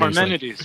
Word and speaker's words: Parmenides: Parmenides.